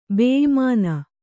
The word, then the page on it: ಬೇಇಮಾನ: kannada